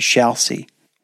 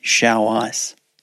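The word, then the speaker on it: Xiaoice: I want to go on record and acknowledge that during the recording of this podcast, I described one of the platforms offering AI companions as Xiaosi, when in fact it is pronounced Xiao Ice.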